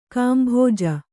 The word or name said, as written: ಗುಂಬಜ್